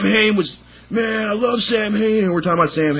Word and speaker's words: Samhain: And, all of a sudden, I'm like, you guys realize that, you don't really pronounce it Sam Hain, it's actually pronounced Samhain.